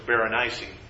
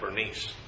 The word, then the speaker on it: Berenice: It would appear that in the Greek and other things, the word is Berenice. I think by the time we get to the translation of the New Testament, we have Bernice. Generally speaking, the extra syllable appears to be in there in the Greek up until at least the beginning of the AD times.